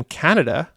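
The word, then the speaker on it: Kaneda: But then when I heard the name Canada, because it's K-A-N-E-D-A, but it's pronounced Canada.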